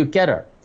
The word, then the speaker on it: Gettr: 我查咗下個發音，仲未有人話俾我哋聽點樣讀，係 GETT R 啊，唔係 GETTER，係 GETTR，叫 Gather。